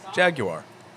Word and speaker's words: Jaguar: The proper way. – Which is? – Jaguar. – Jaguar. Did I say it right? – Yeah.